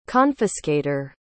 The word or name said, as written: confiscator